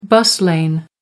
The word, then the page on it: bus lane: American English